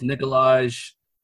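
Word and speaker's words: Nikolaj: It's Nicolaj.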